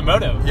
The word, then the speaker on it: Modo: Motto's name is M-A-D-O, and we're in a motto. – Ah, so. – No, I said it wrong. We're in a moto. – Yeah, moto. Yeah. – M-O-D-O.